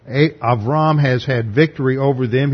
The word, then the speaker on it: Avram: This takes place subsequent to Abram, or Avram as it would be pronounced in the Hebrew, Avram's victory over the four kings that came in and invaded the land.